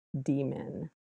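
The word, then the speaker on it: DMin: So the shorthand for that is capital D, capital M, lowercase I-N, usually pronounced D-min.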